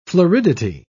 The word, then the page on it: floridity: British English